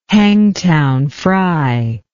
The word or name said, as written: Hangtown fry